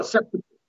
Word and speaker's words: Seppuku: Seppuku? What is it called? – Oh, Seppuku.